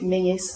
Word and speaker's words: Menzies: M-E-N-Z-I-E-S. One pronounced it Mingus and the other Menzies.